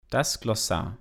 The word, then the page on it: das Glossar: Normal